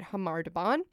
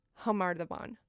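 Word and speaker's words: Khamar-Daban: But as we just heard from the song, it's pronounced Khamar Daban. – Right. – Like an H noise.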